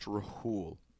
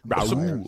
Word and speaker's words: Rhule: It's a big mistake for Matt Rhule. First year, boom. Bad job. – Believe it's pronounced Rahul. – I'm sorry. Rahul. It's been a long day.